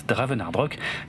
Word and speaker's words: DravenArdRok: Moi c'est Draven et sur Twitter vous pouvez me suivre sur le compte at DravenArdRock, ça s'écrit D-R-A-V-E-N-A-R-D-R-O-K.